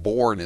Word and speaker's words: Bourne: who thinks that the town of Bourne is pronounced Burn, and who thinks that the state, the Commonwealth of Massachusetts, has a West Coast.